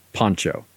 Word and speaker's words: Poncho: So the last character, who they call Poncho, which it's confusing because pronounced correctly, the word Poncho would be spelled with an A, P-A-N-C-H-O. – Yeah. – But they spell like it with Pancho an O. Villa, right? They spell it with an O, which is something you wear, a poncho.